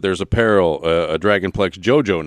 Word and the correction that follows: Dojo: now, right? Dojo, yes. – Excuse me. – Yeah. – I think I mispronounced that.